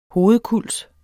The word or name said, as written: hovedkulds